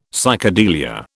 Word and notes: psychedelia: British English

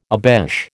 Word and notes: obeche: American English

